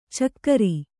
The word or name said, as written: ಛಿಕ್ಕಾರ